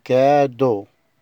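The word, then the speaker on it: cadeau: كلمة رقم 6 كادو. كادو يعني هدية. ثلاث حروف، اخر ثلاث حروف بينطقوا او.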